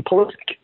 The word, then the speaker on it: Pulisic: And that's how, you know, we tried to get everyone to understand that he pronounces his name Pulisic.